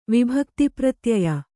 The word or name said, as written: ವಿಭಕ್ತಿಪ್ರತ್ಯಯ